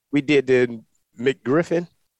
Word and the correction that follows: MacGuffin: or whatever. – MacGuffin. – MacGuffin. – Right. And you almost didn't say it correctly once.